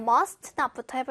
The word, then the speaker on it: must not: 자, 여기 must not 요거 발음할 때도요. st 다 발음하려고 하면 뒤에 not까지 가기가 너무 힘들 거예요. 자, 일단 must not부터 해볼게요. 아, must not. must not. must not. 뭔가 t를 발음을 너무 하려고 하지 마시고요. must not. 요렇게 해도 괜찮아요. must not. 처음엔 그렇게 흘려가면서 하다가 나중에 좀 괜찮아지면 T를 살려도 괜찮아요.